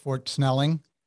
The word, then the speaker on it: Fort Snelling: Now, Fort Snelling, am I saying that right? Fort Snelling? – Yes.